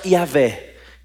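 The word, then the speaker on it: Yahweh: Senhor aqui, caixa alta completa, é a pronúncia Yahvé.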